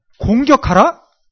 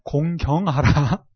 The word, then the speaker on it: 공경하라: 공격하라? 발음을 잘해야 합니다. 네, 공경하라.